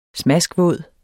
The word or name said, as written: smaskvåd